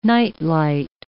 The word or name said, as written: night-light